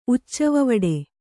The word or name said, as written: ಉಚ್ಚವವಡೆ